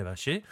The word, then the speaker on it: Valle: Ce club, bien évidemment, c'est Independiente del Vache », euh, ou Del Valle, vous prononcez comme vous le voulez, soit à la platense, soit à la, plus euh, à la, sud-américaine plus classique, on va dire.